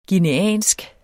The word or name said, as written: guineansk